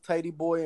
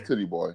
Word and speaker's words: Titty Boy: Hey, wasn't his name pronounced Tidy Boy and we just say Titty Boy? – No, yeah. It was Titty Boy.